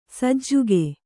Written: ಸಜ್ಜಕ

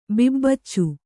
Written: ಬಿಬ್ಬಚ್ಚ

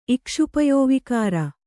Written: ಇಕ್ಷುಪಯೋವಿಕಾರ

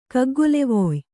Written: ಕಗ್ಗೊಲೆವೊಯ್

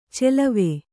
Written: ಚೆಲವೆ

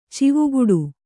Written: ಚವ್ವಗೆಡು

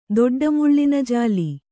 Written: ದೊಡ್ಡಮುಳ್ಳಿನಜಾಲಿ

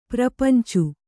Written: ಪ್ರಪಂಚು

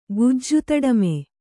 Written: ಗುಜ್ಜುತಡಮೆ

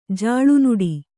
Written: ಜಲನಾಡು

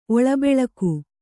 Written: ಒಳಬೆಳಕು

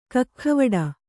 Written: ಕಕ್ಖವಡ

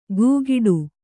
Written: ಗೂಗಿಡು

